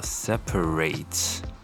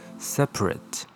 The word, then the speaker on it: separate: 当动词分开的时候叫 separate，那么当形容词分开的、独立的这个意思的时候，它念作 separate。你可以发现后面一个是 ate，一个是 at。